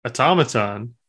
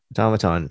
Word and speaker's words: automaton: Automaton. – Automaton.